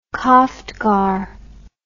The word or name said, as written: koftgar